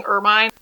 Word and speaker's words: Hermine: And it's, so I would pronounce this Hermine, but they've been saying Ermine.